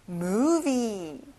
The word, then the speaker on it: movie: 영화, movie. 자, 가운데 V가 있죠? 앞니를 입술에 닿게 해보세요. Movie. That's right.